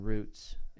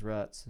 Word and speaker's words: roots: and wanted to be able to put down roots. And where we live right now, everybody says ruts, and it's roots. – Yeah, exactly. – It's roots. – There's two O's.